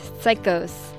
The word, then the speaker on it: Saicos: That was some Peruvian garage music from the band Psicos, but it's spelled not P-S, but S-A-I-C-O-S, Psicos.